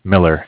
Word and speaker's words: Miller: My name is Zach, spelled Z-A-C-H. – And your last name? – Miller, M-I-L-L-E-R.